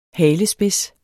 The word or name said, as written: halespids